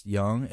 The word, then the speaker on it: Yang: Yeah, yes, Yang, and it spells Y-N-G.